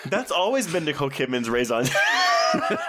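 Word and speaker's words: raison d'être: Yeah, but I feel like that's always been Nicole Kidman's raison d'etre, right? Like, Did I say that right? – No, it's no. Raison d'etre.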